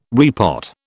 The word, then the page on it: repot: British English